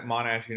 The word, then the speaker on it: Monash: But I have to admit, if it wasn't for the generosity and the vision that the folks at Monash University had in building this tool, yes, I said it right, this university itself was the one that built the simulation.